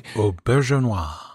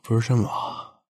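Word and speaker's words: Auberjonois: Aubergenois. – Aubergenois.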